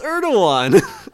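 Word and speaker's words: Erdoğan: How do you say it? Erdogan? – Or is it Erdogan? Oh my God, Erdogan, it's Erdogan.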